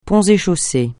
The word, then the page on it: Ponts et Chaussées: francais